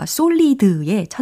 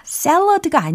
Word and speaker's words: solid: 어, solid의 천생연분이었는데, 자, solid는 영어로 solid, solid. 이렇게 발음 하시면 되겠어요. solid, solid. 아, salad가 아니에요. solid. 라고 발음하셔야 되겠습니다.